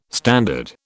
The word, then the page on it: standard: British English